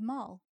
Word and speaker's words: Mal: No, her name is Mal. – Mal. – I've been saying this. – M-O-L-L. Oh, okay. – As in gangsters, gangster's Mal. That's where it comes from.